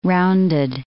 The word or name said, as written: rounded